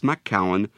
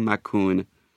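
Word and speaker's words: Macoun: Macaron can be pronounced macaron, but I prefer the French-sounding macaron.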